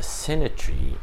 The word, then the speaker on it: Sinai: Remember how the Sinai and the Sinai rhymes with the Hebrew word for test?